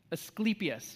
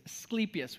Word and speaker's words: Asclepius: Let me see if I'm saying it right. Asclepius. Yeah, there it is. Asclepius.